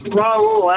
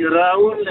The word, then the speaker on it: Raul: Yeah, that's Raul, actually. Raul in Spanish.